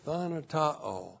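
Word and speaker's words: thanatao (θανατόω): T-H-A-N-A-T-O long O, thanatao.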